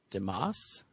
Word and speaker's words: Dimas: His name is Dimas. Is that how you say his name? D-I-M-A-S. – Sure. Yeah, sounds good to me.